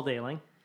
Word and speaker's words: Dailing: Paul Dailing. Yeah, it's like sailing, but with a D.